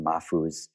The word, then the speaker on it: Mahfouz: Um, and for anyone following along, Mahfouz is spelled M a H F O U Z.